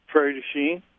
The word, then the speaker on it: Prairie du Chien: Prairie du Chien.